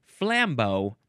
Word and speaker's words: flambeau: Actually, the emphasis is on the first syllable, flambeau. Like Rambo? Rambo covered in cognac and lit on fire? This is French, so it's spelled F-L-A-M-B-E-A-U.